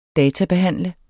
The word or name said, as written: databehandle